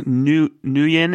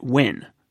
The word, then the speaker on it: Nguyen: As most of our listeners probably know, Flappy Bird is the worldwide sensation from a Vietnamese developer, Dong Nguyen, is how I usually pronounce that. I don't know if that's correct, though. There was a kid in school when I was a kid. He spelled his name the same way, but he just pronounced it Win, like it was. – Huh. – So who knows? Any like naive American who had never been exposed to it be like Nguyen or something, right? – Yeah.